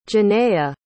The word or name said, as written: Janaya